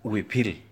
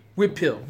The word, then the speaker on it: Huipil: there's an image of a modern Huipil, is that how it's pronounced? – Huipil. Whipil, whipil. Yes, it's okay.